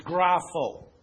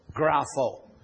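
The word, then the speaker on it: grapho (γράφω): By the way, it's pronounced grapho, grapho.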